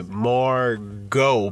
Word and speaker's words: Margot: You say it like this. You say Margot, but you say the T in your brain and not out loud.